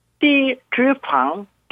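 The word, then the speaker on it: 띠지방 (低脂肪): 발음은 띠지방 저지방 네. 고단백은 까오단바이 매미 저지방 고단백 음식입니다.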